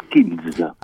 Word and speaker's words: Scills: Alors, est-ce que je prononce bien C'est The Seals Tout à fait, oui. Les Skills. – Ah, les Skills oui, c'est ça. D'accord, donc je, ne prononçais pas bien. Donc euh,